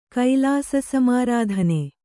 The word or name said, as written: ಕೈಲಾಸಸಮಾರಾಧನೆ